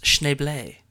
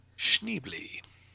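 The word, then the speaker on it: Schneebly: S-H-N-E-E-B-L-E-E. – No. – Go on. – It's S-C-H-N-E-E-B-L-Y. – It's pronounced Schneble. – Schneble. – Okay. – Well, Jack Black might pronounce it Schneble, but it's...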